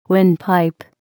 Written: windpipe

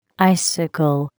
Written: icicle

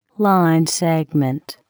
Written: line segment

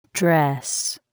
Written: dress

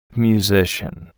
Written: musician

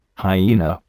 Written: hyena

